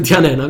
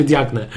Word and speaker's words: Diagne: E, Pierwszym z nich jest e, Diagne, tak się chyba czyta? Diane. E, Diane, na pewno nie czyta się Diagne.